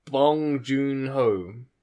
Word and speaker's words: Bong Joon-ho: Bong Joon-ho.